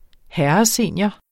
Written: herresenior

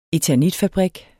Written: eternitfabrik

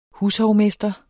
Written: hushovmester

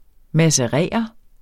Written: macerere

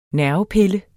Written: nervepille

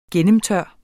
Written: gennemtør

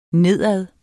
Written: nedad

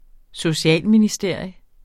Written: socialministeriet

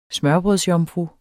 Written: smørrebrødsjomfru

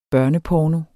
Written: børneporno

